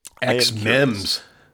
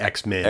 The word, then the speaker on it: xMEMS: XMEMS. Not X-Men.